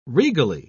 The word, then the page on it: regally: British English